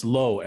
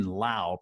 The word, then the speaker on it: Lowe: So the Tampa Bay Rays, Brandon Lau, spelled L-O-W-E. So it's Low everywhere else, right? In fact, there's a Nick Low on the Rays, but Brandon Lau pronounces his name Lau. He's injured. He can't play, but he does get to come out and get introduced to the crowd. And Joe Buck announces him as Brandon Low.